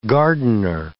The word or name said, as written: gardener